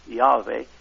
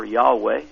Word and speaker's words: Yahweh: It came from a Hebrew word, Yahweh, or Yahweh, it's sometimes called.